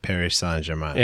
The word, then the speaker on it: Paris Saint-Germain: That's how you say it, Paris Saint-Germain.